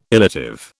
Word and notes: illative: British English

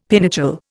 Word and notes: pinochle: Indian

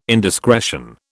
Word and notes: indiscretion: British English

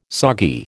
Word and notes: soggy: American English

